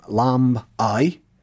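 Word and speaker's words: Lambay: But Lambe um, is an Old Norse name, Lamb I, meaning the island of the lambs.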